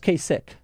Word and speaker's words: Kasich: I may have actually said it wrong this time. – Yeah, it's Kasich.